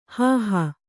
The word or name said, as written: ಹೋಹು